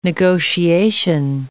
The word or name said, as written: negotiation